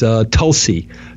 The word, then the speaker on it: Tulsi: What is Tessel tea? – Holy basil is Tulsi. Tulsi tea? – Spell that because I'm not hearing it. – T-U-L-S-I. – Oh, Tulsi. I got it.